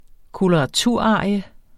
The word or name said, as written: koloraturarie